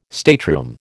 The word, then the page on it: stateroom: American English